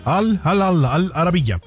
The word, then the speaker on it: Al Arabiya: Bueno, sabrá cómo se dice, pero es Al Arabiya.